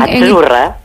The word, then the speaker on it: Azzurra: Azzurra. – Azzurra. Mira, ¿ves? – Azzurra. Es que... Azzurra. Sí, como porque pizza. La Z no es la Z como es, decimos en catalán tan sonora, no.